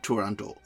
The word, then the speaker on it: Turandot: Right now we're going to talk about Liu in Turandot. It's hard for me to say Turandot, but I follow the Rosa Raisa dictum that Puccini himself pronounced it without the final T.